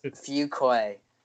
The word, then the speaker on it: Fuqua: No, it's Fuqua.